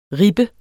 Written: rippe

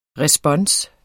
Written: respons

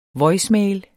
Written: voicemail

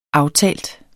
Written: aftalt

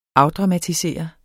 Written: afdramatisere

